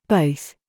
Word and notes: BOB: British English

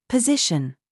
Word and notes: ZOOS: British English

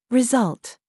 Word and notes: ZOOS: British English

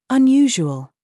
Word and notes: GENRE: British English

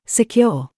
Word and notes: CURE: British English

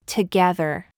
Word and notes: gig: American English

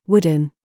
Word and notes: FOOT: British English

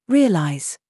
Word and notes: ZOOS: British English

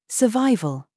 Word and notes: VERVE: British English

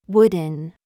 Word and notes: foot: American English

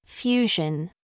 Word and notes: genre: American English

